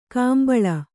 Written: ಕುಂಬಳಿ

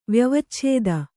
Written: ವ್ಯವಚ್ಛೇದ

